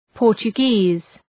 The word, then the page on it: Portuguese: {,pɔ:rtʃʋ’gi:z}